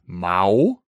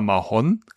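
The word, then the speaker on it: Mahón: Einwohnerzahl rund 95.000 Menschen und die Hauptstadt ist Mao oder Mahon, kann man unterschiedlich aussprechen, Mao im Osten und genau.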